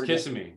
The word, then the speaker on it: Kissimmee: to uh, Kiss, Kissimmee and we had another we have another place we stay in Kissimmee that Kissimmee. Is that how you say it? Help yeah, me out. Kiss, Kissimmee. Yep kissing me.